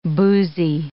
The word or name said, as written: boozy